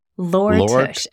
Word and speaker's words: Lore Tush: Lore Tush, L-O-R Tush.